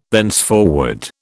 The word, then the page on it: thenceforward: British English